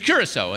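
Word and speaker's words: Curaçao: Now, I would never have pronounced Curacao the right way, but it was Curacao, and I'm like, I don't know where that is, and it's C-U-R-A-C-O, Curacao, and I'm like, oh, Curacao, all right, that's the right way to say it.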